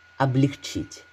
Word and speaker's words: облегчить: Облегчить. Зачастую это слово все только усложняет. По нормам ударения ставится на последний слог.